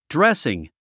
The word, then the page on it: dressing: drésiŋ; ドレッスィン